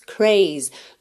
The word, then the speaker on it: craze: Então, ele é dance, termina no som s, craze, termina no Z, craze, craze. Listen to us.